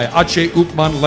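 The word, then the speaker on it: H. Upmann: Upman, or the correct Cuban pronunciation, my H. Upman Legacy.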